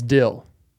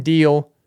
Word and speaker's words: Dyl: And first off, it's No Big Deal. It's D-Y-L. It's Dill. D-Y-L is Dill. It's not Deal.